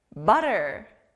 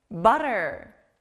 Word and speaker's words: butter: 친구들, 여기는 아주 부드러운 T 소리가 나요. 우리가 빵을 만들 때 버터를 넣죠? 버터, 영어로 butter. butter. butter.